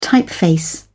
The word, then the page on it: typeface: British English